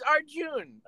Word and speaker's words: Arjun: Two syllables. Okay. Glenn, come on! It's our June. – Artune. – Arjun. – Arjun. Okay, his name's Arjun.